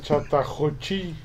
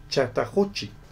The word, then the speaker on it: Chattahoochee: cuyos cuerpos fueron encontrados en el río Chattahoochee, o cómo se pronuncia, Chattahoochee. – Chata, ¿cómo? – Chattahoochee. – Chattahoochee.